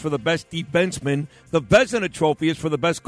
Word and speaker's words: Vezina: No, just tell me how to say it. – It's the Vezina Trophy.